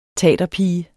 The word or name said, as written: taterpige